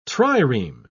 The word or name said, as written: trireme